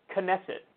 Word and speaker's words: Knesset: But it's pronounced Knesset. And what I would do is I would read it and I'd go, that K is definitely silent.